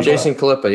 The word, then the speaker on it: Khalipa: Is it Khalipa? Jason Khalipa. – Jason Khalipa. Yeah.